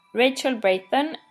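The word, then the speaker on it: Rachel Brathen: So in English, it's Rachel Brayton. I've been Rachel almost my entire life, but in Swedish, it's actually pronounced Rakel. Last name in Swedish is Brotén.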